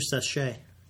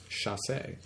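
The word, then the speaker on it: chassé: How's your sachet? – My sachet? Actually, it's the correct pronunciation is chasse.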